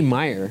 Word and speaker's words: Mayer: No, in the commercial they spell it M A. But they say Mayer. Mm-hmm. – Yeah. – Kids are saying Meyer.